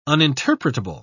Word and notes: uninterpretable: British English

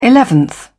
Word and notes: eleventh: British English

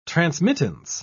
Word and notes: transmittance: British English

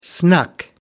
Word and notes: snuck: American English

